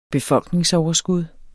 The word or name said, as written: befolkningsoverskud